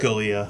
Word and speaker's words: Scalia: Scalia.